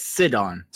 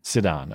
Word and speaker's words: Sidon: It's Sidon. – Sidon. Okay. – Yeah, like sit on. Sidon.